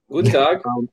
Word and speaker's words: Guten Tag: Is that how you say it? Guten Tag? – Yeah, Guten Tag. – Guten Tag.